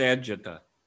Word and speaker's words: Agata: I think it's pronounced Agita. – Agita? – No, Agita. – Agita? – For our Italian friends.